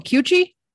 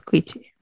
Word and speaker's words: Queechy: Q-U-E-C-H-E-E. – Queechy. – Queechy? – Queechy. – All right. I was going Queechy, but no, that didn't sound right. – No. It's Queechy.